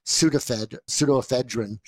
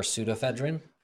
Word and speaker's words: pseudoephedrine: Why would it be P? For pseudoephedrine? – Well, I guess, yeah, you're right. – You think they're going to take a silent P and put it in size 48 font right on the box? – You would never guess I was valedictorian.